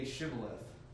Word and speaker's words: Shibboleth: say Shibboleth. They couldn't say it right. I'm sure you may have heard this before, but they could say it all but one letter. That H, they couldn't pronounce it.